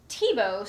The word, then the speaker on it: Thibault: Okay, so I asked her how to pronounce her last name because it's spelled a little bit differently, and of course our track record with pronouncing names. So it's pronounced Thibault. So it's Jessica Thibault, and she is a local author.